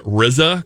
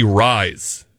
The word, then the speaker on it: RYZE: It's funny, it's spelled like RYZE, so I was pronouncing it RZA, because Wu-Tang ain't nothing to bleep with. – There it is. – And then the fiancé was like, hey, dum-dum, I think it's meant to be Rise, which makes sense, because it's supposed to be like all-natural coffee.